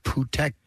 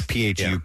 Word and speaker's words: Phuket: It's actually pronounced Phuket. – Well, that was sort of a go-to to people who wanted to passively curse the air. – Yes, because it's Phuket.